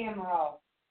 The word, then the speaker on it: Amaral: My name's Christy Amaral. Christy with a K, ends with a Y. A-M-A-R-A-L. I know you're not going to spell that. Portuguese.